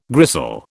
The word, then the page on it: gristle: British English